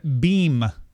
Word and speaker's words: Beme: CNN announced Monday that it has acquired Beam. That's spelled B-E-M-E.